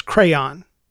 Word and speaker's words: crayon: And it's crayon. It's a two-syllable word, not a one-syllable word.